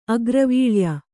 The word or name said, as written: ಅಗ್ರವೀಳ್ಯ